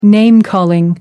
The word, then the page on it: name calling: American English